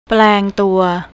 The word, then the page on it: plangtua: thai